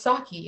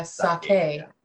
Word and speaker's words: sake: I thought it was pronounced sake. It's not. It's pronounced sake, K-A-Y. That's how it's supposed to be said.